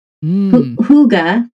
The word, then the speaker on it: hygge: Hygge, you know, H-Y-G-G-E, for those who have not learned how to pronounce it. That's a hard word to say, but it's allegedly Hygge.